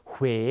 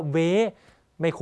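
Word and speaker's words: Huế: ลาเราออกเสียงเนี่ยเราต้องห่อปากด้วยนะครับดูตัวอย่างคำแรกพร้อมกันฮวาฮวาสังเกตปากผมห่อด้วยนะฮะฮวาแปลว่าดอกไม้นะครับยกตัวอย่างอีกคำหนึ่งนะครับคำว่าเหวหรือที่คนไทยเรียกว่าเวไ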